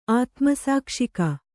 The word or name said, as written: ಆತ್ಮಸಾಕ್ಷಿಕ